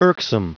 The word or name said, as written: irksome